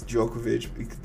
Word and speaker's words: Djokovic: I think it's Djokovic. The D is silent.